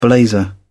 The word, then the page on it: blazer: British English